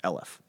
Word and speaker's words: aleph: So, now, some people will say that the word for 1,000, okay, the Hebrew word for 1,000 is the word aleph, and it's basically spelled A-L-P, aleph, that probably would require some explanation why P makes the F sound.